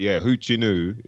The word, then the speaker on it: Hoochinoo: He says, so I guess this is how you'd say, that's how it's spelled. Hotsnuwu, but Hoochinoo is what how you'd say it.